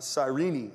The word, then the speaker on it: Cyrene: As they went out, they came upon a man from Cyrene, is actually how you pronounce it, named Simon.